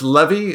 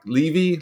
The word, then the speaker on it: Levey: Directed by one J, I believe it's pronounced Levy. It's spelled L-E-V-E-Y. Levy, Levy.